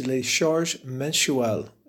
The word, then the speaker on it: les charges mensuelles: If I pronounce it correctly, in French, it's les charges mensuelles.